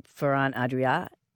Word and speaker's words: Ferran Adrià: I read in a Guardian piece about, I'm not sure I'm pronouncing his name right, Ferran Adrià of El Bulli.